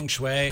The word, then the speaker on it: Peng Shuai: Yeah, Peng Shuai.